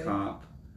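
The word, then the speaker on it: Kape: Manuel, I think he said Cop. Manuel Cop. It's spelled Cape. – Cape. Oh, yeah, but I did. – I think it's pronounced Cop. A lot of the people say it differently.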